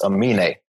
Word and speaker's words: Aminé: It's Aminé. I listened to his new album. – Anime? Is that his name? – It's Aminé.